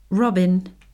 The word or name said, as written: robin